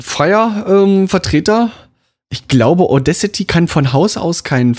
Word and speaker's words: Audacity: Audi, nee, Audio City heißt das. Das ist ja wirklich Auda. Auda City, Auda. Audacity.